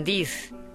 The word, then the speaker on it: this: Ojo, la pronunciación del i en this, esto o esta, es corta. El plural se pronuncia con un i largo.